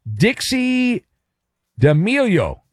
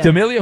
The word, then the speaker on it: D'Amelio: Dixie D'Amelio. Is that, I'm saying that right? Yeah, but 25. Is that an actress? – D'Amelio.